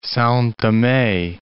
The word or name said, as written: Sao Tome